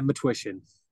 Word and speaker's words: Matwishyn: Yeah, Matwishyn.